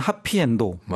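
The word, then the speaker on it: 하피엔도라고 (はっぴいえんど): 이분은 이제 하피엔드, 그러니까 영어로 하면 해피엔드인데 일본어로는 하피엔도라고 맞아요.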